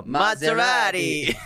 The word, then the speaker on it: Maserati: Maserati.